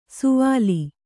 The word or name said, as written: ಸುವಾಲಿ